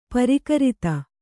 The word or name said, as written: ಪ್ರಾಕೃತ